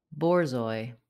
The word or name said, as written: Borzoi